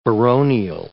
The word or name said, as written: baronial